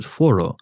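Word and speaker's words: Fårö: You know, if you read it as is written, Faro. I would pronounce that Faro or Faro, but if you hear it in the movie, then they actually pronounce it Foro.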